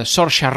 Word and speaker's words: Saoirse: Això és Lady Bird, amb 5 nominacions als Oscars sota el braç, entre les quals les de millor pel·lícula, actriu per Saoirse Ronan. Ho he dit bé, Marta, després de la lliçó que ens vas fer la setmana passada, com pronunciar aquest nom. – Sí, ho has dit bé.